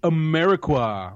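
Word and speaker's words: Amarikwa: Quincy Ameriqua, which is how he himself pronounces his name.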